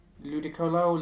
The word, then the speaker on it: Ludicolo: Uh, so it is, I think it's Ludicol. I never know how to pronounce that one. – Let's have a look. Ludicolo, Ludicolo.